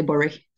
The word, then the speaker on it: Bury: Yeah, well, we were very close to each other because I was in Bury. Although, if I'm saying it properly, I should say Bury. Bury, Bury.